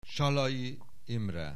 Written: Sallai Imre

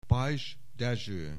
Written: Pais Dezső

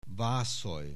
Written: Vazul

